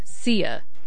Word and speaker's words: Sia: I actually just Googled how to pronounce that, and I found out that I have been saying it wrong this whole time. I have even corrected people who said Sia and told them it was Sia. So, to all those people, I apologize, because my YouTube pronunciation that I found said it is definitely Sia.